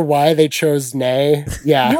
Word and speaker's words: Ney: The town was called Nay. – Nay. – Like N-E-I-G-H? – It was spelled N-E-Y. – Oh my God. – And so no one ever knows how to pronounce it correctly. – Nay.